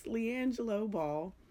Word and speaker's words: LiAngelo: I keep messing up his name. Am I saying it right? Yes, LiAngelo Ball.